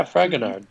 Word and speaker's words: Fragonard: Jean-Henri Fragonard. Once again. – Oh, yes. Yeah, Fragonard.